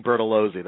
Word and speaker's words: Bertolozzi: I say Bertolozzi. That's the English pronunciation.